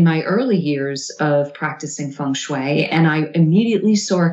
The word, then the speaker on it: feng shui: I didn't read much about feng shui.